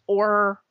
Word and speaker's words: Auror: They didn't say, but Harry is I can't pronounce it right, but it's Auror or something. It's A-U-R-O-R. That's how you spell it.